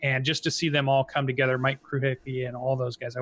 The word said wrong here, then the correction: Krahulik: Mike Krahulik, yeah. – Krahulik, yeah.